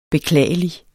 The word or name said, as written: beklagelig